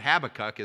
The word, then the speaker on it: Habakkuk: There's two pronunciations, but Habakkuk is probably the way it should be pronounced.